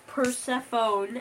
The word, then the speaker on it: Persephone: I heard that Persephone, as you call her... – that's how you say it. – That's how you called her. – Because it looks like it says phone at the end.